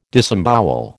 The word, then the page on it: disembowel: American English